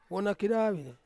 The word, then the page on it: wonakedawi: Ansus